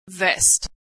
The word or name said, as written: vest